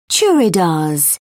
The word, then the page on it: churidars: British English